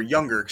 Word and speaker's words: Junger: Sebastian Junger, or Younger. It's pronounced like a Y, but it's spelled with a J.